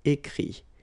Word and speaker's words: écrit: IRE here, IT, but then phonetically it's I as well because you don't pronounce the final T, okay? Écrire, écrit. I-R-E-I-T, same group.